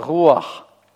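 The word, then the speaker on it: руах (רוּחַ): Беглый патах никогда не принимает ударение, поэтому нет слова «руах» в древнееврейском языке, есть слово «руах». Руах, понятно? Или руах, да.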